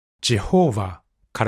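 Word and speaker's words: Jehovah: それを英語に訳した、ジェホーバーから、エホバという名前が来ています。古代ヘブライ語で神の名前がどう発音されていたか正確にはわかりません。しかし、英語では長い間、ジェホーバーという形が使われていて、 1530 年にウィリアム・ティンダルが刊行した翻訳聖書に初めて登場しました。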